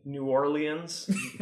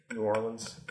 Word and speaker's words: New Orleans: New Orleans. New Orleans. Orleans. Any number of ways are acceptable.